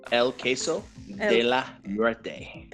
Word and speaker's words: El Queso de la Muerte: So there's an L in there. – There's an E-L.